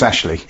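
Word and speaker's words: Aisling: Now, Chris Pollard says it's Ashley, A-S-H-L-E-E, that is written A-I-S-L-I. – So it's pronounced Ashley. – Yeah, so there's no H, is there? – No, but that doesn't matter.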